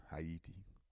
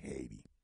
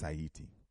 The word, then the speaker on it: Haiti: And, of course, Sanjay, I met Sister Gabby here in Atlanta, the work that she's doing in Haiti. That's how you pronounce it. It's not Haiti. Haiti. It's Haiti. You know, and also to my good sister, who's Haitian.